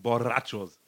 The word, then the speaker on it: Borrachos: Also ich habe jetzt am Wochenende gelernt, es wird ausgesprochen Borrachos. Man muss das eher rollen, weil es zwei sind.